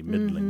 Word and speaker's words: middling: Middling, by the way, just for everyone, is we're not pronouncing the G at the end, but it looks like middling, mm-hmm. – M-I-D-D-L-I-N. Middling.